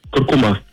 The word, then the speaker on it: куркума: Несмотря на то, что большинство произносит данное слово с ударением на последний слог, акцент в нем все же стоит на втором. Правильно говорить куркума, представляете?